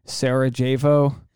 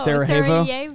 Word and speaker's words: Sarajevo: If you've ever seen one of those war torn movies where they're in like Sarajevo, is that how you pronounce it? No, Sarajevo. Sarajevo.